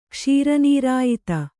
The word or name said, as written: ಕ್ಷೀರನೀರಾಯಿತ